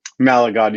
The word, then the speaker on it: Malagon: Did I say that correctly? – Malagon. You went really Spanish and ethnic with it.